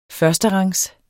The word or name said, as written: førsterangs